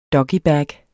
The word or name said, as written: doggybag